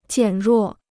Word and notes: 减弱: jiǎn ruò

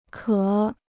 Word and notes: 壳: qiào

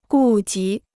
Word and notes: 顾及: gù jí

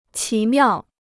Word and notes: 奇妙: qí miào

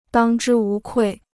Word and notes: 当之无愧: dāng zhī wú kuì

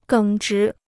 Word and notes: 耿直: gěng zhí

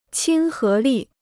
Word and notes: 亲和力: qīn hé lì

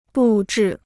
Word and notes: 布置: bù zhì

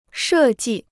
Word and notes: 设计: shè jì

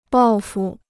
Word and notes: 报复: bào fù

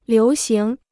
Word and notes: 流行: liú xíng

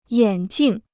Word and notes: 眼镜: yǎn jìng

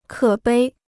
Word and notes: 可悲: kě bēi

